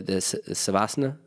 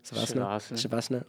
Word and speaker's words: Shavasana: The savasana, am I saying that right? Savasana? Shavasana.